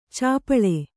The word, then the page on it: ಛಪ್ಪಳೆ: kannada